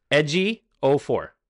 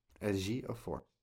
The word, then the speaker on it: Ejiofor: I know how to pronounce it, by the way. I looked it up on IMDb. His name is pronounced Chiwetel Ejiofor. – Ejiofor.